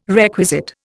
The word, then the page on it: requisite: Indian